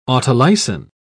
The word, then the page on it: autolysin: British English